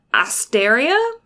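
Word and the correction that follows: Astrea: Astraea. – There we go. That's how you say it.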